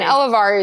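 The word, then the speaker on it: Elevare: And Elevari is spelled like elevate, but with an R. E-L-E-V-A-R-E.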